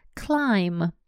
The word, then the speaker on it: climb: Climb. climb – взбираться. Обратите внимание, в этом слове буковка B конечная не произносится.